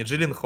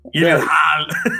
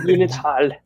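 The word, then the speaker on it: Gyllenhaal: У него на самом деле настоящая, ну, по-настоящему его фамилия считается не Джиллин Да, а, да, да. – Гилленхаль. – Гилленхаль.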